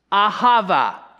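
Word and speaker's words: ahava (אהבה): The Old Testament word for love is this, ahava. Can you say that? Ahava. Yeah, it rhymes with guava. That's how I remember it.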